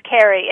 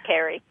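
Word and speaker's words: Ceri: It's Welsh. It's Celtic. And in Wales, the C-E is a hard C. So just like in your head, just imagine it's K-E. But yeah, it's Carrie. It's a funny spelling.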